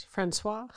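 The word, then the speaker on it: François: I'm using the English pronunciation, but it's probably said something different in France. Francis the First might be like, I don't know. – It was Francois. – Yeah. Francois, something like that.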